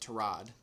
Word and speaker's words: Tyrod: He's the number one overall pick, whether it's next year or who knows what happens with Tyrod Taylor, which, by the way, is how you say it, Tyrod. – Yeah, apparently everyone's been saying Tyrod, and apparently that's been wrong.